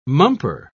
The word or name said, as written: mumper